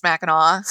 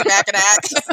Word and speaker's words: Mackinac: Well, the number one thing to know if you're not familiar is that no matter how it's spelled, it's pronounced Mackinac. we know you're not from here if you say Mackinac.